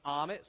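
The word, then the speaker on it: Ahmet: My first name is Amit, so it rhymes with words like comet and vomit.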